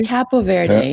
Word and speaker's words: Cabo Verde: Can Algeria pull a Capo Verde or Cape Verde or... How are we supposed to pronounce it? – Is it Verde? – Cabo Verde? – I say Cabo Verde. Yeah, Cabo Verde. – But they put a B like Cabo San Lucas, right? – Cabo, so.